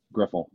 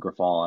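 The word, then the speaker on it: Grifol: I always mispronounce the manager's name for the White Sox. Somebody help me out here. – Griffle? – Griffle, yeah. I always want to call him Griffal.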